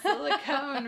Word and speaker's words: silicone: If I want to use my teacher brain, cone is a VCE word, so should have a long O and a silent E. – Okay. silicone reusable bags.